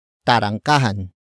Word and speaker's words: tarangkahan: It is the focus marker that introduces the main topic of the sentence, and that is tarangkahan.